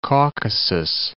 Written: Caucasus

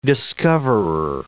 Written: discoverer

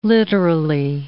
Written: literally

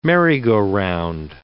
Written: merry-go-round

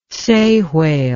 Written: sei whale

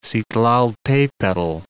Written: Citlaltepetl

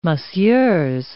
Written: messieurs

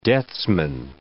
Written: deathsman

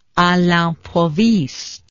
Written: a l'improviste

